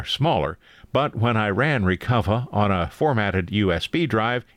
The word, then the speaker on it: Recuva: A program you might think should be pronounced recuva is actually pronounced the way a Bostonian might say recover, recuva.